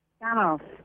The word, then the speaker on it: Spannaus: Help us with the pronunciation of your last name, by the way. – Spannaus. It's actually of German origin.